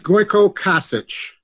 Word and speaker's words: Goiko Kosic: And Ben, my name is pronounced Goiko Kosic. It's like crotch itch, but it's cock itch.